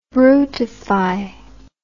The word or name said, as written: brutify